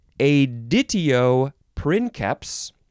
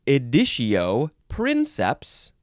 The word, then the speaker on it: editio princeps: two words, you can pronounce it either editio princeps or editio princeps. Maybe you can combine them in different ways, but those are the two main ways. It is two words, like I said, editio or editio, E-D-I-T-I-O. Second word is P-R-I-N-C-E-P-S, princeps or princeps.